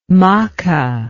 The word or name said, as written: macke